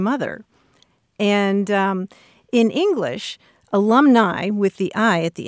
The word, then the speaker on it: alumnae: If they're only female graduates, then you want the feminine ending, which is AE and is pronounced uh, alumni.